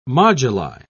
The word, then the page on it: moduli: British English